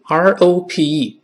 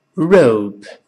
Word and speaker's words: rope: 一点播就会了，所以女孩子的名字 R O S E 读成 Rose，绳子 R O P E 读成 Rope，而不是 Rope。